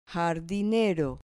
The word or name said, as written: jardinero